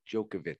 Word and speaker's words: Djokovic: Djokovic.